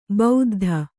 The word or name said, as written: ಬೌದ್ಧ